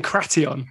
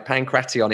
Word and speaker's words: Pankration: Pankration. – Yeah, that's right. Pankration.